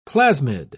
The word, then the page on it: plasmid: British English